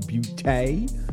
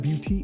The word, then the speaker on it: Beauté: You know, YSL Beauté. Is that Beauté? Is Beauté? Am I saying that right? Yes, okay.